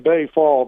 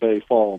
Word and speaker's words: Baye Fall: I call him Bay Fall, B-A-Y-E, last name F-A-L-L. if his first name's pronounced differently, I've always heard it Bay Fall. But Bay Fall.